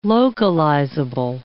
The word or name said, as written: localizable